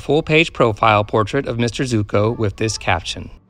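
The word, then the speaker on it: Zucco: George Zuko, pronounced Zuko. He was another of the character actors who never achieved real greatness, yet his face graced many a horror film of the 1940s.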